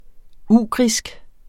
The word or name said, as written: ugrisk